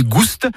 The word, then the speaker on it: goust: Ni goût ni goust, avec prononciation du S originel que l'on retrouve dans le provençal goustus, goûteux, et qui a été remplacé depuis par l'accent circonflexe dans notre orthographe moderne.